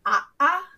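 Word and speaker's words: a'a (ʻaʻā): For example, the pokey kind of lava is called a'a, and okay, that is written a Okina, which looks like a backwards apostrophe.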